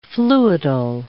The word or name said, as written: fluidal